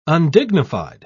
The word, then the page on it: undignified: British English